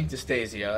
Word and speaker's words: Distasio: But, yeah, so this is Anthony Distasio.